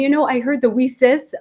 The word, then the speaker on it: WiCyS: We often go by our acronym, WISIS, and we spell that W-I-C-Y-S, pronouncing it WISIS like we sisters, because we're a global cyber sisterhood.